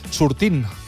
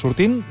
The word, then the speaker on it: sortint: Sortint, per exemple. Mm-hmm. Sortint, doncs aquesta T que s'escriu doncs no es pronuncia.